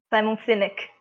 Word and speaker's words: Simon Sinek: Você levantou um ponto muito bacana que o Simon Sinek, acho que é assim que se pronuncia? – Simon Sinek. – Mas é, Sinek. é, tá ótimo, tá ótimo. É Sineco. Boneco.